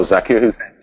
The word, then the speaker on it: Zakir Hussain: Oh, Zakir Hussain.